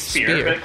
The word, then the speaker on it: Spier: I said that wrong, Ezra. – It's Spier. Close enough.